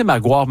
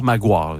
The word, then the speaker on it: Maguire: Puis là, on dit, le prononcer Maguire, Maguire. Inquiétez-vous pas, on le prononce comme il faut.